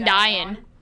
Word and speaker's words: Dion: It looks like it would rhyme with lion, but it doesn't. If Dion rhymed with lion, it would be Celine Dion.